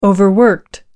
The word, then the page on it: overworked: American English